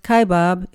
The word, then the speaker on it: Kaibab: Roll the Paiute word kaibab over your tongue a few times to get the unique flavor of the Indian name for a very special place. Pronounced kaibab, it's short for the mountain lying down,